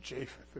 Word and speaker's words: Japheth: Noah had three sons, Ham, Shem, and Japheth, or Japheth, if you prefer.